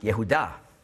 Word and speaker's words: Jehuda: Und Judas, das ist einfach die griechische Aussprache von Juda, der gleiche Name. Also in Israel hat man damals Judas genannt, Jehuda.